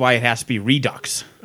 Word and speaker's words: Redux: I think it should be pronounced Redo. I think the X should be silent, but apparently it's Redux.